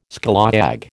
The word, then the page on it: scallawag: American English